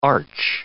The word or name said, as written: arch